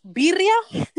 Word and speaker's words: birria: It's birria.